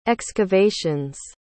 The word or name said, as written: excavations